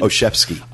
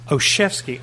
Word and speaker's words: Olszewski: I'm glad you're pronouncing his last name, too, because it's spelled Olszewski. How are you saying it again? – Olszewski. – Olszewski. I never would have come up with that. – Well, neither did I until the sales girl told me how to pronounce it.